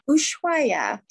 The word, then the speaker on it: Ushuaia: Ushuaia